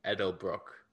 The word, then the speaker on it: Edelbroek: So it's Dr. Egbert Edelbrook.